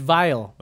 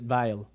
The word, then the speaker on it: Weil: His name is Weil. – Ooh. – With a W, though, so you have to pronounce it Weil. – Well, yeah, because W's are pronounced V's, like Steve Weil, Volkswagen.